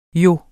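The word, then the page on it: jo: Danish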